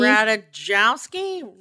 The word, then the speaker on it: Ratajkowski: Is that how you say it? Ratajkowski? – Ratajkowski? – Ratajkowski, yeah.